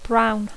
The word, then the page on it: brown: English